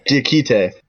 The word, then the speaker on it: Diakite: Now, we got to talk about, probably briefly, because I think he's on a two-way contract, so he might not play, is Mamadi Diakite? Is that how you say it? – Diakite. Yeah. Here, I'm gonna pull up some notes.